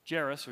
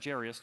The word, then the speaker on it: Jairus: named Jairus, or Jairus, depending how you say it, his daughter has died.